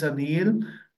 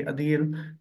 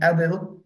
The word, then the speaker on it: Adeel: My name is Adeel. The pronunciation is basically Adeel. So some may pronounce it Adil, but that's fine too.